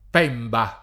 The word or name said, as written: Pemba